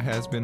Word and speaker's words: Hazbin: I'm definitely gonna check out Has Been Hotel. – It's spelled H-A-Z-B-I-N. – H-A-Z-B-I-N. Oh, nice.